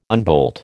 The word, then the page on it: unbolt: American English